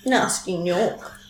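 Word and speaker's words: Gnasty Gnorc: Spelled with a silent G, like gnome or knife. – Nasty Nork.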